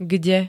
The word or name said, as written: kde